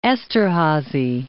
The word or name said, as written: Esterhazy